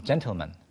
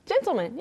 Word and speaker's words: gentleman: 이게 신사를 가리키는 gentleman이라고 하지 않는 것처럼 gentleman. – gentleman.